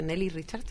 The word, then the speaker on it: Nelly Richard: Hoy día justo estaba leyendo un texto de Lenny Richard, de Nelly Richard, ahí lo dije bien,